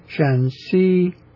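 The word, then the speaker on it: Shaanxi: And then the one north of that is also spelled similar in English to Shanxi, but it is pronounced differently as Shanxi. – Shanxi with an ascending syllable as the second syllable. No, it's not. Wait a minute. It's not ascending. It's not. Shanxi, it's the higher pitched, even pitch, the second syllable. But the shan is not even pitched.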